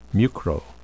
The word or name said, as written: mucro